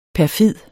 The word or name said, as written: perfid